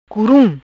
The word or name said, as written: Curum